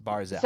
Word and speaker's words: Barzal: And I always say his name wrong, and I blame my mom for this. Barzal. I'm saying it right the time, right? – Yes, Barzal.